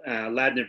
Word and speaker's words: Ladner: When you were a kid, you know, growing up in Ladner, BC, right? I said that right. I'm not messing that up. – Yeah, you nailed it. Yeah, that's it.